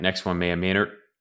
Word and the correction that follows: Maya Minor: Minor, man, she's going to be so mad. She actually tweeted at me last time I mispronounced her name. Maya Minor.